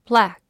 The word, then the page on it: plaque: plǽk; プラァク